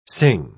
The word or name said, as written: sing